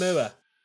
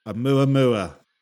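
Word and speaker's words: Oumuamua (ʻOumuamua): Oumuamua. – Oumuamua.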